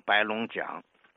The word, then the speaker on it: 白龙港: 有一个叫白龙港，这个港呢，当地人读成白龙江。